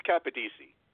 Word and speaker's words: Capodici: It's Capadisi.